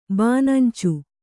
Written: ಬಾನಂಚು

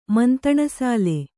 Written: ಮಂತಣಸಾಲೆ

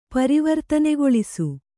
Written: ಪರಿವರ್ತನೆಗೊಳಿಸು

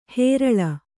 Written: ಹೇರಾಳ

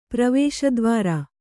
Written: ಪ್ರವೇಶದ್ವಾರ

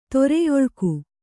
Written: ತೊರೆಯೊಳ್ಕು

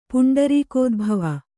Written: ಪುಂಡರೀಕೋದ್ಭವ

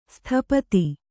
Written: ಸ್ಥಪತಿ

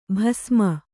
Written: ಬಸುಮ

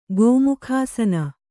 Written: ಗೋಮುಖಾಸನ

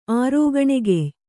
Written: ಆರೋಗಣೆಗೆಯ್

